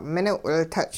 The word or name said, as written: mining'-wiltuch'